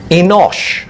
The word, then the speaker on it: Enosh: And how is this word pronounced? This word is pronounced like this. Enosh.